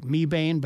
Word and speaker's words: Mebane: So you have to be a local to be able to pronounce that because it's M-E-B-A-N-E. It looks like Mebane, but it's Mebane.